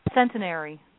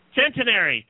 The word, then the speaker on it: Centenary: How do you say that? – Centenary. – Centenary. Thank you.